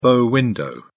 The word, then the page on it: bow window: British English